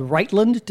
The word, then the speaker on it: Rightlynd: It is the sixth in a series of plays set in a fictional Chicago neighborhood called Rightlynd. Did I say that correctly? – You did.